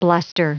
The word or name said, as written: bluster